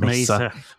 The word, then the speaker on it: Mesa: Is that how you say it? – It's Mesa.